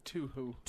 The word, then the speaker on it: Touhou: Tuhu? T-O-U-H-O-U. – I think Tuhu.